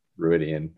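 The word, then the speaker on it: Rhuidean: Like so having anything that kind of touches on that more, I feel like the only time you get that is that Ruidian, or however you say that, right? – Those flashbacks, really. – That is an acceptable pronunciation, I believe.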